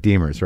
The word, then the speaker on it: Demers: I'm saying your name right, Demers, right? – Demers.